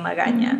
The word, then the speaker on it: Magaña: And it happens to rhyme with my last name, Magaña.